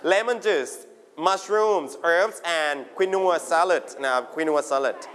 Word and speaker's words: quinoa: อันนี้เราเรียกว่าสมุนไพรภาษาอังกฤษเรียกว่าอะไรสมุนไพรอ่านได้สองแบบอ่านว่า herbs โอเค everybody herbs ถ้าเป็นอเมริกันจะไม่ออกเสียงตัว h เหมือนคำว่า hour ที่แปลว่าชั่วโมงอ่านว่า herbs herbs นะสุดท้ายอันนี้น่าจะเป็นคำศัพท์ใหม่สำหรับเด็กๆนะครับผมอันนี้ออกเสียงว่า quinoa แบบอังกฤษหรือ quinoa quin quin แล้วก็ wa w a wa quinoa quinoa